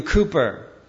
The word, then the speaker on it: Cowper: Years ago, there was an individual by the name of William Cooper. If you see his name in print, it looks like Cowper. It's the way I mispronounced it for years.